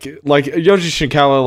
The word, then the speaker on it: Yoji Shinkawa: No, I said his name wrong. No, I did. – That's his word. Yeah. That's his name. – For some reason, I thought it was something else. Like Yoji Shinkawa, like concept art.